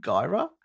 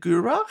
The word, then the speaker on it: Guyra: I had a real problem with the name Gyra because I kept calling it Gura. It's spelled G-U-Y-R-A because I'm thinking of Gura cheese.